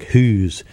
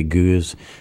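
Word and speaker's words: Gueuze: And what the Goose is, or as the Flemish say their G's like an H, like who's, but the Americans and British say Goose.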